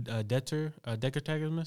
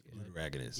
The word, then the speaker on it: deuteragonist: He's a new Decker Tagamus, so that's how you say it. – Deuteragonist.